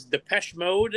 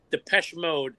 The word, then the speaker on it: Depeche Mode: Before you get into it, I'll never forget on one of the local radio stations, they did not know how to pronounce Depeche Mode, and they called it Depeche Mode, and it was hilarious. – Yeah, because it's French, and I took French in high school.